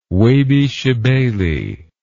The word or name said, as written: Webi Shebeli